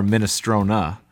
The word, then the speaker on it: minestrone: Minestrone is pronounced minestrone or minestrona. However, many people in America pronounce it minestrone. But that's not how it's pronounced in the old Italian ways.